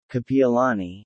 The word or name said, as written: Kapiolani